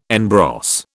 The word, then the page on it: en brosse: British English